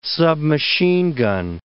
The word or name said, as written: submachine gun